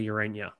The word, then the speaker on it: Ureña: No, and Jacob in the comments is saying, you know, the Mets need to go after Ureña, or Jose Ureña. That's how you say it. He has the tilde.